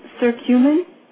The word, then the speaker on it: curcumin: One of the things that I hear a lot about is curcumin.